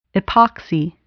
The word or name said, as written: epoxy